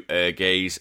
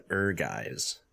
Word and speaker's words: Ehrgeiz: Ehrgeiz. – I've always said Ehrgeiz. – I didn't even know how it's pronounced. – It's German from what I understand, and I think it means...